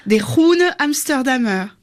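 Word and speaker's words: De Groene Amsterdammer: On a retenu une belle enquête, une jeune journaliste néerlandaise de l'hebdomadaire des Groene Amsterdammer, je ne sais pas si je prononce bien. – Des Groene Amsterdammer. – Ah oui, c'est mieux.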